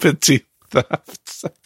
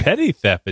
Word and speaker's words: petty theft: Is it petite theft? Petit theft. How do you say petit theft? Second degree. – Petty theft is usually what it is. – That sounds... That's right.